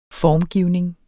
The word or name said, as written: formgivning